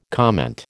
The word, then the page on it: comment: American English